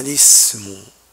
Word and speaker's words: الاسم: فهمزة الاسم همزة وصل ليست همزة قطع، ولهذا لا تذكر إلا في أول الكلام.